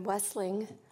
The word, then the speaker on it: Wessling: No, sir. My name is Erin Wessling, WESSLING.